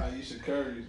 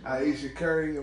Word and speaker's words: Ayesha Curry: Ayesha Curry. – Ayesha Curry.